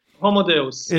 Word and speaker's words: Homo Deus: Um, and so thank you so much also, you, you, know, the, the book Homo Deus. Am I pronouncing that right? Deus? Yes. – Homo Deus.